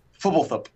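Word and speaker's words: Fblthp: According to Mark Rosewater, who I believe is the foremost expert on Ravnica pronunciation, it is Fubblethup.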